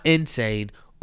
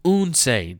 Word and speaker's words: Unsane: The movies I saw this week, I saw Unsane. Not Insane, Unsane. Yes, I said that correctly.